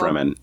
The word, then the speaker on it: Fremen: Are we just going to have like a happy montage where he gets to know the Furman Sand people and just like... – The Furman? – The Furman Sand people. – Fremen. – You say Fremen, but they said... They pronounce it Furman every fucking time.